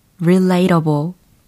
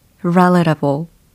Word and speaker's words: relatable: be relatable 혹은 be relatable 두 가지 발음이 다 가능합니다. 예, relatable 혹은 relatable.